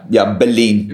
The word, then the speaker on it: Bellin: Yeah, Bellin, but that's right, yeah.